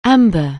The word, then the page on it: amber: anglais